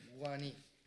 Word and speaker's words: Guarani: Ein paar Jahre wart ihr ja dort unten unter den Guarani. Das A spricht man, glaube ich, nicht mit, richtig? – Guarani. – Genau.